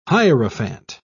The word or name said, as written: hierophant